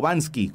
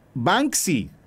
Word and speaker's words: Banksy: ¿Banksy o Bansky? ¿Cómo se dice? Yo digo que es Banksy, pero no, me crean, tengo 42 años. Banksy. Ah, chingada madre. Qué, bueno eres, mira. A ver, es Banksy.